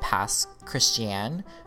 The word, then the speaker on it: Pass Christian: Oh yeah, and so if you don't understand, the city I grew up in is called Pass Christiane, but it's spelled Christian, like literally the name Christian.